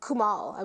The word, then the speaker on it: Kumail: Like this little banter that they do, um, Issa and it, Kumail, Kumal? – Kumal, I believe is Kumal. how you pronounce it.